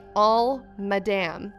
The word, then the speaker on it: Isle Madame: The island is spelled Isle Madame, but we pronounce it like All Madame.